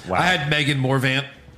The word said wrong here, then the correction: Morant: There's no V. – Megan Morant. – Oh, Morant, yeah.